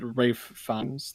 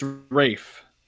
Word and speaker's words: Ralph Fiennes: I've heard many people pronounce it Rafe Fiennes. Let me just. Yeah, it's Rafe. – Rafe. – There's an L right there. – Yeah, but for some reason he pronounces it as R-E-I-F. That's what it says on Wikipedia.